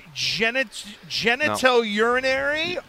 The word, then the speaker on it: genitourinary: No, I think it's genitourinary. – That's correct.